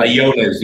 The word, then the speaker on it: Allones: Allones. Yep, like a Y.